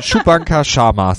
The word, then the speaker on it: Shubhankar Sharma: Shubhankar Sharma. – So, ich hätte jetzt am Anfang gesagt Chewbacca, aber das war was anderes. Das war jemand anders. Shubhankar Sharma, so heißt er, keine Witze mit Namen, also auch nicht böse gemeint, lieber Herr Sharma.